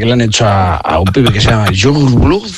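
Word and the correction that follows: Yungblud: Es que era muy difícil de pronunciar, pero luego ¿cómo se pronuncia? – Youngblood. – Ah, vale, pero ya está. – Youngblood.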